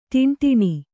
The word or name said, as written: ಟಿಂಟಿಣಿ